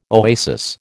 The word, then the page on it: oasis: American English